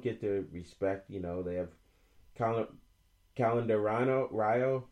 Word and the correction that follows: Candelario: I'm saying it wrong. Candelario. Candelario, thank you.